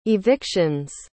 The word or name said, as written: eviction's